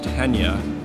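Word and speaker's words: Chania: Spelled Kenya, but pronounced Henya.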